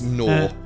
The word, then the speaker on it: Gnaw: So we're talking about Gorn, if that's how you pronounce it. I'm really terrible at names. – It's Gnaw. Gnawing on a bone.